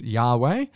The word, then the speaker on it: Yahweh: And so instead what we were left with is just the four consonants in Hebrew, YHWH, and there weren't any vowels at that point. And that has led to a bit of kind of questions as to, well, how do you pronounce the Lord's name? And so we can take a good guess that it's Yahweh, although